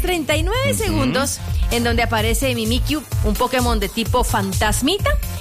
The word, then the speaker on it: Mimikyu: Mimikyu. – ¿Cómo? – Mimikyu. – Mimikyu. – Yo digo que así se pronuncia. Mimikyu.